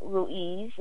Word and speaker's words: Ruiz: It's by Don Miguel Ruiz. And let me spell that for everybody. It's Don, D-O-N. The middle name is M-I-G-U-E-L. M-I-G-U-E-L. And the last name is spelled R-U-I-Z, Ruiz.